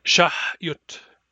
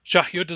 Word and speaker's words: shahyut: Shahyut is a very important word that is very frequently mispronounced. It has a back H, a hard Y, and a short U, all of which frequently get mispronounced. It should be pronounced shahyut.